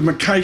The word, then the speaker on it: Mackay: Eric Kozatsky joins us with Mackay Shields. Am I pronouncing that right? – You got it. – Mackay, I nailed it.